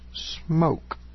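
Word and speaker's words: Smooke: After I thought about this man, I realized it was Dr. Well, it's spelled here Smook, S-M-O-O-K-E. I thought about this man, I realized it was Dr. Smook. However, she said it's pronounced smoke.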